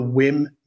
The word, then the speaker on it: WiiM: It's called the Wim Mini. Wim is spelt W-I-I-M, and Mini, obviously Mini.